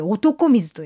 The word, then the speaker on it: 男清水: このアーケード街のところにキュッとこうどなたでもどうぞ状態でこの湧き水出してありまして、道の片方は男清水と書いて男水と呼ぶ、